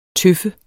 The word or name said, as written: tøf